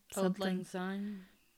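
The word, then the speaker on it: Auld Lang Syne: People sing like the odd ling sign. I never say that right. May all acquaintances. – Old ling sign. – Yeah, that sounds right. Old, like a u l old Auld, sign. sign.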